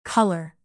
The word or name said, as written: color